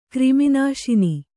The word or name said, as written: ಕ್ರಿಮಿನಾಶಿನಿ